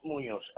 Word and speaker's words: Munoz: Luis Munoz, L-U-I-S, and then Munoz, M-U-N-O-Z.